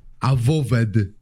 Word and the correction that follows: Avowed: Awo, awowed? Tak, się, tak to się mówi? – Avowed. – Avowed.